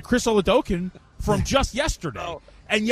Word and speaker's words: Oladokun: The official pronunciation is Oladokun.